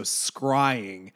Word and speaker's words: scrying: It is literally like a crying shame, but instead of crying, it is with an S. So scrying shame.